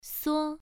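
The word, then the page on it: suo: tone 1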